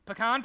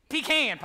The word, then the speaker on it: Pecan: We got pecan pie. Oh, I'm sorry, I said it wrong. Pecan pie. That's how they say it down the South.